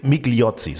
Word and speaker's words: Migliozzi: meine, äh, Guido Migliozzi ist äh, Migliozzi, so, so ist glaube ich richtig ausgesprochen.